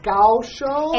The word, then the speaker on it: Kaohsiung: If it's called Kaohsiung, why is it spelled with a K and H S I U. Like it's such a weird. Okay, that's Gaoshou. It's Gaoshou.